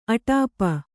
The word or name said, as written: ಅಟಾಪ